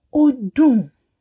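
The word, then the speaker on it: ó dùn: You will say odun. Odun. Odun. Dun actually means to be sweet, but it is also used as delicious.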